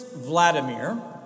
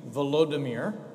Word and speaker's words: Volodymyr: In the year 988, Prince Vladimir, or if you prefer the Ukrainian pronunciation, Volodymyr, you've heard that comparison before, I'm sure, chose to be baptized.